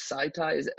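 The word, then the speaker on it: SCTIX: I think it's pronounced Sci-Tie. It's S-C-T-I-X.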